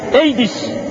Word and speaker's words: AIDS: A, İ, D S dört tane harfin bir araya gelmesiyle ifade edilen bir hastalık var. Ne diyorlar? Siz söyleyin. AIDS, A, I, D, S. AIDS diye okunuyormuş. Amerika'yı kırıp geçiriyor.